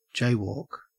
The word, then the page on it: jaywalk: Australian English